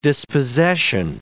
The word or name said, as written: dispossession